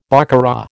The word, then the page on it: baccarat: American English